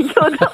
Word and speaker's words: 효도: 호도, 네. 호도가 아니라 효도죠? 아, 네, 효도.